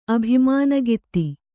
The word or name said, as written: ಅಭಿಮಾನಗಿತ್ತಿ